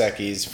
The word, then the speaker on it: Equis: You know, La Liga MX, which uh, Dos Equis for you podcast listeners who don't know how the X is pronounced in Spanish, Equis.